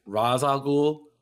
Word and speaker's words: Ra's al Ghul: They were like, no, actually, it's Ra's al Ghul, the common mispronunciation.